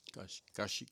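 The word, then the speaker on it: Cascia: Nosotros pronunciamos Casia en español, pero es Casia, ¿verdad? Había un sacerdote que estuve escuchando que decía es Casia. – Cashi, Cashi, Cashi.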